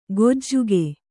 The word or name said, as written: ಗೊಜ್ಜುಗೆ